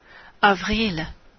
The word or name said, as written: avril